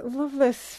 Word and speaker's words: Lovelace: Well, apparently the current Lovelace family pronounces it Lovelace.